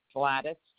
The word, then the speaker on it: Gladys: Gladys, G-L-A-D-Y-S. Thomas, T-H-O-M-A-S.